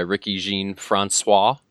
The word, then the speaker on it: Ricky Jean Francois: His roster spot's being taken not by Shea McClellan, but instead by Ricky Jean Francois.